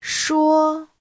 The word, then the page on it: shuo: shuo1